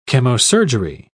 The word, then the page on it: chemosurgery: British English